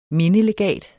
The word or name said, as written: mindelegat